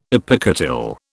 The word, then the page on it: epicotyl: British English